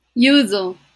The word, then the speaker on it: Yuzo: Que está dirigida por Yuso Itami. Tú corrígeme todo lo que pronuncie mal. – Yuzo.